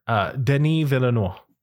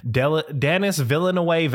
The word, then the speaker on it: Villeneuve: I honestly had to look up how to pronounce his name today because before today I was using my Kentucky brain, and I've been pronouncing it in like a full Peggy Hill voice, just Denis Villeneuve.